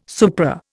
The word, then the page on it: supra: Indian